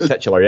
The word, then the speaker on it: titular: titular, or is that how you pronounce it? – Yep, titular, yep.